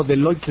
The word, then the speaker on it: Deloitte: De paso le digo, Deloitte se escribe D-E-L-O-I-T-T-E, Deloitte.